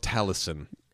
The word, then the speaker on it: Taliesin: Yeah, The Book of Taliesin.